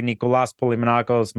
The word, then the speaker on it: Nicholas Polimenakos: Thanks for saying my name correctly, Nicholas Polimenakos.